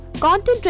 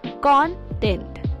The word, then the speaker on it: content: When used as a noun, the stress is on the first syllable, content. For example, the contents of the box were scattered all over the floor. His article is well written, but it lacks content. The first syllable rhymes with Don and John, while the second syllable sounds like tent. But when content is used as an adjective or verb, the stress shifts to the second syllable.